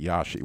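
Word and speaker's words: Rashi: Is they pronounce it Yashi, Y-A-S-H-I.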